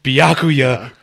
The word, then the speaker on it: Byakuya: I'm sorry, how the fuck did you just pronounce his name? – I don't even know. Byakugan? – It is Byakuya Kuchiki, sir. Kuchiki. And I will thank you to pronounce it properly.